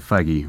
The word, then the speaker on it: Feige: It was, as Kevin Feige, that's how you pronounce it, Feige, right? – Yeah. – Okay, just making sure here. Yep. First time I ever said it out loud in public, I think. – Lord Feige, yes. – Lord Feige, Lord Emperor Feige.